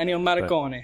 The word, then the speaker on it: Ennio Morricone: And I love the score from Ennio Morricone. I don't know how to pronounce his name. – Yeah, Ennio Morricone.